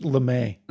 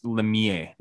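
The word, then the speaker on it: Lemire: I think it's pronounced Lemay. – Lemay. It's no, that's Lemier. Jeff Lemier.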